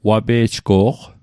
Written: wape'jgo'q

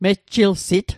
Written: mejjilsit

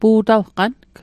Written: pu'talqang

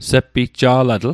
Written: sepitja'latl

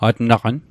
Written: atnaqan

